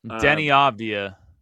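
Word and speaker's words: Deni Avdija: And the how do I pronounce the Israeli kid's name, Kev? – Denny Avdija.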